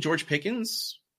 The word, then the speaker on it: George Pickens: I do believe in George Pickens. I said that right. I'll never say Pickens or Pickett and not think I said the wrong one.